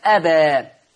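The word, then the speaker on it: أبى: هذه الياء مرسومة وتنطق ألف، تنطق ألف، الكلمة رسمت بياء خلاص، ولكن أنها ملفوظة ألف أبا أبا.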